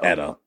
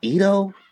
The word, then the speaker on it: Edo: Edo.